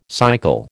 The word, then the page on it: cycle: American English